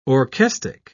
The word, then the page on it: orchestic: British English